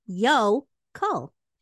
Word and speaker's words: yokel: It's spelled Yokel, and it's pronounced yo-kul.